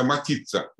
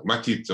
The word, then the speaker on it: матица: Э, по-русски, кстати говоря, матрица иногда матица называется. Матица, вот, с ударением на «и», матица.